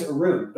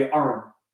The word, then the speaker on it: Arune: And my name is Arun, but if you talk to some Indians, it'll be Arun. – Mm-hmm. – You know, and like, that's valid too. I just don't say my name that way.